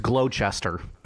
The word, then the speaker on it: Gloucester: It's Gloucester. Final answer. – I believe it's Gloucester, and the guy was ripping you for your adding a W and they're making it Gloucester.